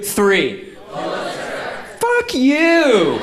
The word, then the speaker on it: Pulitzer: Um, th- they explain very carefully at the ceremony that it's like Pulitzer.